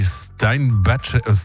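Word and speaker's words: Steinbachek: Bronski Beat gründete er 1983 in London zusammen mit den Keyboardern Steve Bronski und Larry Steinbatschek, so wurde ausgesprochen.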